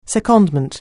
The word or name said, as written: secondment